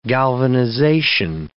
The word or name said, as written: galvanization